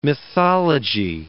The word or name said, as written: mythology